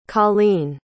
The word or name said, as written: collene